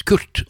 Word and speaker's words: QRT: Det tittelen på en melodi fra en CD-plate som heter QRT, og det kan jo veldig lett uttales Kurt, og det meningen også.